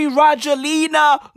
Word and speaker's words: Rajoelina: Bruv, Andre Rajoelina looks 12.